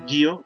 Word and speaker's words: Gyo: Digo, recomiendo que empiecen por Gio, porque es se escribe G-Y-O, porque pues es como cortita y pues va empezando como a dar esa introspección del tipo de terror que él maneja.